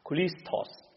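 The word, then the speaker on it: Christos (Χριστός): 基督就是耶稣的头衔啊，也是希腊文的这个发音啊， Christos。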